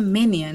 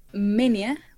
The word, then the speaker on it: Moenia: Moenia que creo que se pronuncia Menia, ¿no? Por, por este simbolito que tienen pero pues como nadie sabía qué onda, pues dijeron, no, Moenia. Y pues se quedó Moenia.